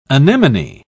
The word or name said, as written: anemone